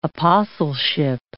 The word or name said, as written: apostleship